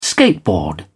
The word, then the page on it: skateboard: British English